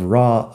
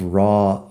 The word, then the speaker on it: raw: Raw es R-A-W. O sea que se escribe casi igual a saw, el pasado de si. Saw, raw, law, de ley terminan en este AW tienen un sonido parecido. O oh, al final, que no es AU. Sé que de cómo se escribe podrías pensar que es AU, pero no, es O.